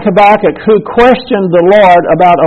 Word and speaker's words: Habakkuk: There's a book called Habakkuk. And I used to remember how to spell it because it's capital H-A-B-A-K-K-U-K.